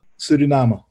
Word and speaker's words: Suriname: Suriname.